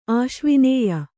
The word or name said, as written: ಆಶ್ವಿನೇಯ